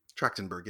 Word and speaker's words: Trachtenberg: Michelle Trachtenberg. Did I say that right? – Trachtenberg, yeah.